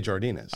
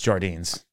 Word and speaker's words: Jardines: So how do you say Jardinus? I say Jardinus. – I think it's Jardines.